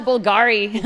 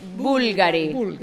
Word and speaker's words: Bulgari: Bulgari. – Oh, we call it Bulgari. So bad. Bulgari. So there's one that doesn't have a second to last stress syllable. Because it's Bulgari.